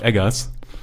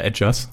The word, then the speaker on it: Eggers: Robert Eggers, oder Edgers, ausgesprochen mit Doppel-G.